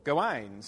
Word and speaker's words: Gawain: Gawain, um, from the rhyming based uh, poems, especially those which are derived from the French, um, the emphasis tends to be on the last syllable, Gawain, so that you can rhyme it with other things,